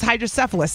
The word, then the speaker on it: hydrocephalus: Her son has hydrocephalus.